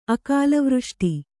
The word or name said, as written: ಅಕಾಲವೃಷ್ಟಿ